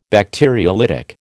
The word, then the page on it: Bacteriolytic: American English